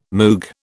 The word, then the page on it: Moog: British English